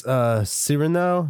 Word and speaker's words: Cyrano: It's Cyrano. Cyrano. Like as in serenade. Cyrano. Cyrano.